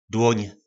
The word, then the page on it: Dłóň: nářečí Po našimu